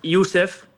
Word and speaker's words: Yusef: Me dicen Joseph, como que le pronuncian como se lee en argentino, en realidad es Yusef, pero me dicen Joseph.